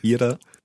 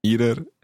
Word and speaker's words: Ihrer: So that word, Ihrer, let's hear it in good pronunciation. – Ihrer. – Ihrer.